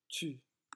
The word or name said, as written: tu